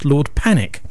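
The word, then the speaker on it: Pannick: It's spelt P-A-N-W-I-C-K, but it's pronounced Lord Panic.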